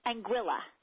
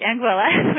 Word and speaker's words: Anguilla: How do you pronounce it? – Anguilla. – Like Anguilla. Oh, great. Anguilla,